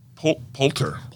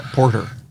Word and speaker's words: Poulter: He was there's an L in there. – Poulter. – Poulter. – Will Poulter. I'm sorry. – Not Porter.